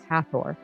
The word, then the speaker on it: Hathor: I thought it might be Hathor based on some of the other pronunciations of Egyptian deities, but I Googled it. I did research. I Googled pronunciation. It is Hathor.